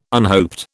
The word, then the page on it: unhoped: British English